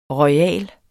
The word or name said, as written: royal